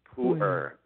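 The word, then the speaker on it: Pu-erh: Pu'er.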